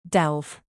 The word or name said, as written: delve